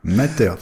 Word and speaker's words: Matter: Oui, bah, y a des nouveautés qui vont arriver sur Matter. Ça te va comme ça? Matter? – Matter, très bien. – Ouais, on vous explique, c'est parce qu'il y avait débat sur la prononciation de ce standard qui, voilà, qui est promis pour l'automne prochain.